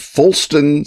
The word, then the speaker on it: Fuggleston: Yeah, he's born in 1552 in Quidhampton, which I am assured is in a parish named Fulston St. Peter. And you say, what's so funny about Fulston? And the answer is, it's spelled Fuggleston St. Peter, which you ask, why does he want to leave town?